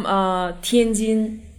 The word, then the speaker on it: Tianjin: So I'm from Tianjin.